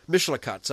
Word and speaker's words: Mishlakat: Mishkalat Malakei Royim. Presumably. Mishlakat, sorry.